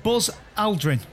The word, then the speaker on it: Buzz Aldrin: Buzz Aldrin.